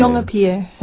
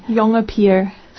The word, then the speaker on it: Jongepier: Jongepier. – Oh, that's why I can never say it. – No, see, people pronounce it. – Her name is really hard to say. Say it again. – Jongepier. – See, but how do you spell that? – J-O-N-G-E-P-I-E-R.